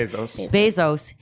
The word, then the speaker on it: Bezos: She went after Jeff Bezos because Bezos.